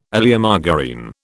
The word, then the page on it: Oleomargarine: British English